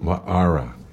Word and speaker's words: Waara: C-H-R-I-S-T-I-N-E. And Wara is spelled W-A-A-R-A. Often it's pronounced here in Maui often as Wara, because... We pronounce our vowels, all of them. – Wa'ara. Yeah.